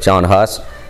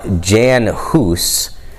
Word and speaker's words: Jan Hus: John Huss, and his name is actually pronounced Jan Hus, but you know, most of us pronounce it John Huss.